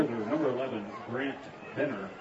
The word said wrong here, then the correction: Brant Benner: And correction on that, it's going to be Brant Benner on the pronunciation there.